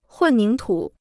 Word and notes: 混凝土: hùn níng tǔ

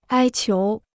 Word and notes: 哀求: āi qiú

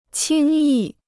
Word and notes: 轻易: qīng yì

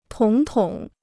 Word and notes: 统统: tǒng tǒng

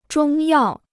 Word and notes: 中药: zhōng yào

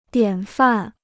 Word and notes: 典范: diǎn fàn

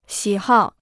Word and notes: 喜好: xǐ hào

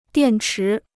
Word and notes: 电池: diàn chí

